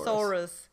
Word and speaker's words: thesaurus: Thesaurus. It's like a dinosaur, but with many words.